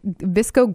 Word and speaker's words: VSCO: V-S-C-O. It's pronounced Visco, first of all. And it means basically like Visco girls wear oversized shirts and scrunchies, Birkenstocks.